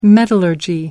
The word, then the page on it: metallurgy: American English